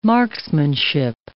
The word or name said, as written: marksmanship